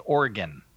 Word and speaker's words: Oregon: Tim in Oregon, Father, it is pronounced Oregon. I have learned over the years.